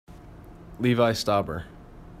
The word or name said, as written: Stauber